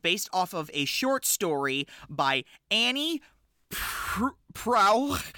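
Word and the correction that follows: Proulx: Yeah, I couldn't wait to hear you try and pronounce Prue. This woman's last name. – Is based off of a short story by Annie Prue.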